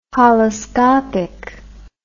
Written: holoscopic

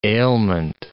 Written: ailment

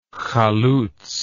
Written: chalutz

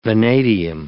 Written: vanadium

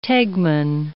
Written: tegmen

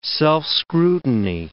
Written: self-scrutiny

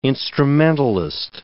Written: instrumentalist

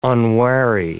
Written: unwary

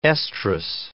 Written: estrus

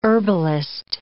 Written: herbalist

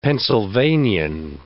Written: Pennsylvanian

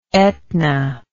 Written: Aetna

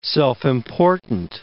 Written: self-important

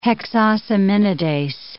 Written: hexosaminidase

